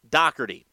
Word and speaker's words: Doherty: Yes, the name is spelled Doherty. I actually was an intern in the Cape Cod League while he was an intern there, so I know it is pronounced Doherty.